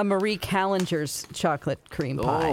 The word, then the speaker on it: Marie Callender's: Most people would call it Calendars, but my mom says Marie Callender's, and we lived in California, and I know the first restaurant is by the Queen Mary, so I think it's pronounced Marie Callender's.